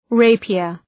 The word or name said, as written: rapier